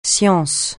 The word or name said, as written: science